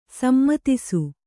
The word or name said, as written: ಸಮ್ಮತಿಸು